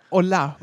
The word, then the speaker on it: hola: Das heißt dann aber hola, weil das H ist stumm und die Betonung liegt auf der zweiten Silbe.